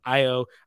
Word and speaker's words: Ayo: Ayo.